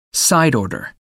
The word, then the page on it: side order: American English